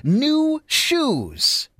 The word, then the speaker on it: Nu Shooz: New Shoes, John Smith and Valerie Day. And by the way, that is spelled N-U-S-H-O-O-Z. They spell it kind of funky, kind of cool.